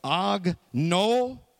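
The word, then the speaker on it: Agnoeo (ἀγνοέω): It's pronounced like this for Ben Turner's Greek. Agnoeo. Pretty good, huh? You know, you're going to learn Greek tonight. Agnoeo.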